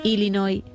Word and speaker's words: Illinois: Él, español, ella estadounidense, concretamente de Illinois, y fue quien grabó este audio para que escuchemos cómo se dice realmente.